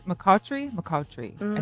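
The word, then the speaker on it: McCoughtry: McCautry, I think is how you pronounce it.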